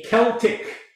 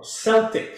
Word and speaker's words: Celtic: Well, it turns out they were a, a Celtic or Celtic, some people pronounce it Celtic or Celtic, tomato, tomato, doesn't really matter, Celtic people dwelling in Galatia, a region of central Anatolia surrounding present-day Ankara.